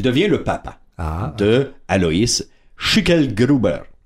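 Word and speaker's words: Schicklgruber: Schickelgruber.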